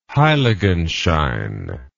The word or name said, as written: heiligenschein